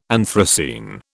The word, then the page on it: anthracene: British English